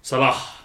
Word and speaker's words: Salah: Salah.